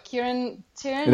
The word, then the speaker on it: Kieran Tierney: I'm sure I'm pronouncing him wrong, but Kieran Tierney? – That's right. That's correct.